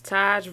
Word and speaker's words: Tajh: That was Taj Virgil. Is that how you say his name? T-A-J-H.